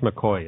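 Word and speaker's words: McCaughey: So it actually checked on this, and I'm convinced they were right that this Betsy, I can't remember, McCoughlin, was that? – It's pronounced McCoy. It looks like McKay.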